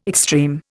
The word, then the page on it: extreme: Indian